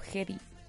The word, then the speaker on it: Hedy: Y no es Heidi, es como Hedy.